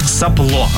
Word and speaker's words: сопло: Сопло, ну, честно, наверное, так и произносим. Сопло, правильное ударение.